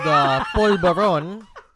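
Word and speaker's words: polvorón: Is that called polvoron? – What's it called? – Polvoron. – Polvoron? – Yeah. – Did I say it right? – Yeah, you said it right.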